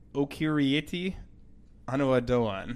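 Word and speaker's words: Okieriete Onaodowan: We've got, I'm going to butcher this name, and I apologize, Okirieti Anuadoan. – I think that was a pretty good job.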